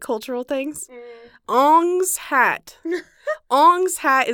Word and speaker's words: Ong's Hat: It's spelled O-N-G-S, Ong's Hat, as in hat belonging to Ong.